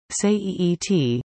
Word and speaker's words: Suet: In the Lekwungen language, Suet, pronounced Seeet, means truth.